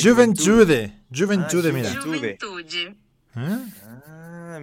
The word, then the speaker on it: Juventude: Juventude.